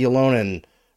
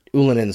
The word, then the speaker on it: Ylönen: Jesse Ylönen, or Ylönen, sorry, pronunciation always gets me.